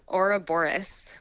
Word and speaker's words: Ouroboros: Can you pronounce this for me? Because I'm worried I'm going to mispronounce it. Yeah, Ouroboros. Ouroboros G's. – See, I'm happy you said it because I think I would have said like the outer boroughs.